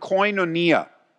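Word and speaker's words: koinonia (κοινωνία): It's the Greek word koinonia. Koinonia. You can spell it K-O-I-N-O-N-I-A.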